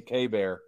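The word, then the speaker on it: Keibert: Kybert. Oh, that last week I said Kybert. And I, we know Brad corrected me, K-Bear.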